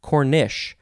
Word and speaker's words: cornice: C-O-R-N-I-C-E, cornice, or you can pronounce it Cornish with an S-H, Cornish.